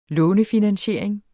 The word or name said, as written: lånefinansiering